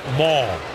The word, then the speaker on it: Moll: Sam Maul, you spell his last name M-O-L-L, but pronounced Maul.